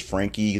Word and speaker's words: Franke: It is Franke. The E is pronounced.